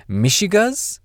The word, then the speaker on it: mishigas: This is another specific one, so don't know what this is, but it is spelled, well, not spelled, it's pronounced Mishigas, and it's spelled M-I-S-H-I-G-A-S.